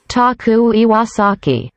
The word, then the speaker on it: Taku Iwasaki: Taku Iwasaki.